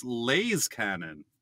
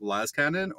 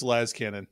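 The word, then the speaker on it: lascannon: Should it be pronounced correctly, like Laz Cannon, or moronically like this? – Sorry, but that guy. Like, seriously, what a bad opinion. Like, come on. Everybody knows it's Laz Cannon.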